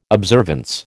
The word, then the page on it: observance: American English